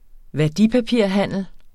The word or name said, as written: værdipapirhandel